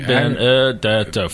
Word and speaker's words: DiBenedetto: I'm DiBenedetto. DiBenedetto. Five syllables.